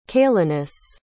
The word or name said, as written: caelinus